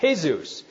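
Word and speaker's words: Jesús: He was Hispanic, named Jesus, J-E-S-U-S, right? And in the Hispanic community, you pronounce that Jesus. But he always liked to introduce himself as, can you guess, Jesus. All right? And that's the English pronunciation, so okay.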